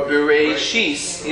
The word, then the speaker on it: Bereshit: Bereshit. Or Bereshis. Either Bereshis or Bereshit.